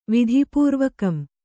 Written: ವಿಧಿಪೂರ್ವಕಂ

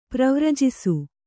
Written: ಪರಿವರ್ಜಿಸು

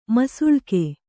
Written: ಮುಸಳಿಕೆ

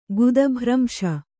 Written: ಗುದಭ್ರಂಶ

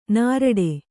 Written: ನೀರಾಟ

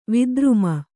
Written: ವಿಧರ್ಮಿ

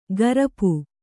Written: ಕರ್ಪು

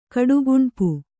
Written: ಕಡುಗುಣ್ಪು

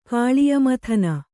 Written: ಕಾಳಿಯಮಥನ

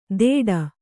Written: ಥೇಟ್

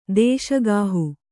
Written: ದೇಶಗಾಹು

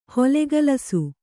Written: ಹೊಲೆಗೆಲಸ

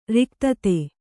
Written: ರಿಕ್ತತೆ

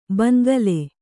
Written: ಭೋಂಕಲ್

